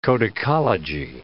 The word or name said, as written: codicology